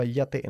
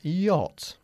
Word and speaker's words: yacht: ¿Y cómo se pronunciaba yate en inglés? – Yate. – Yate, no, se escribe yacht, pero se pronuncia yacht.